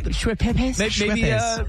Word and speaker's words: Schweppes: Maybe it's the Schweppes. How do you say that? – Schweppes. – Schweppes.